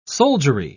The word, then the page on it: soldiery: British English